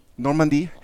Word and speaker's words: Normandie: Normandie.